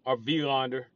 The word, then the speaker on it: Wilander: Or Wilander. It's a W, but I think it's pronounced V.